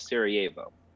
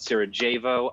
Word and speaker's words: Sarajevo: Yes, and for those of you who are wondering, the location of this attack was the city of Sarajevo. – Yes, I have to try very hard not to call it Sarajevo. I pronounce J's very hard, and it is screwed up more than one foreign word.